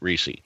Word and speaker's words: Recce: His last name is R-E-C-C-E. So I'm not going to you, Reese. He pronounces it Reese.